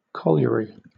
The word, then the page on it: colliery: British English